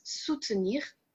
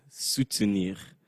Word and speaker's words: soutenir: So when you're trying to say that, you would use soutenir. Not supporter. – Mm-hmm. Yeah. Difficult one to pronounce there. Soutenir. – Soutenir. – Yeah. Soutenir. Sounds very French.